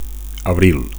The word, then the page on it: abril: Catalan